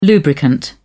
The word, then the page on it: lubricant: British English